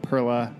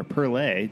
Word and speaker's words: Perle: Uh, the original was uh, Cascade Perla, or oh, Perla, or Perle, depending on how you say it.